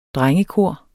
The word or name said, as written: drengekor